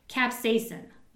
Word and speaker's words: capsaicin: I don't even know how to pronounce it. Capsaicin. c-a-p-s-a-i-c-i-n. Capsaicin. That's how I say it, all right?